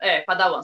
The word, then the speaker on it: Padawan: É Padawan, né? – Padawan, só muda a pronúncia.